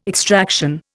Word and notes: extraction: Indian

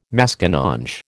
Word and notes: Maskinonge: American English

